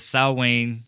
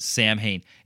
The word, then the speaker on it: Samhain: because it's the Samhain slasher, not Samhain. I fucking hate it when people pronounce it Samhain.